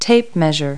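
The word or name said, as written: tape measure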